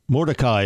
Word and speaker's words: Mordecai: Yeah, Madaki, is that how you pronounce it? I get it all confused. – Mordecai is how you pronounce it.